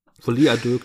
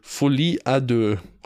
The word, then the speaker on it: Folie à deux: Folie à deux klingt gut. Folie à deux. Offen also offiziell jetzt, äh, das ist die einzige richtige Aussprache.